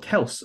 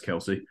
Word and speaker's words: Kelce: The Travis Kelce, or Kels, apparently, is actually how you pronounce it, but everyone just says Kelce.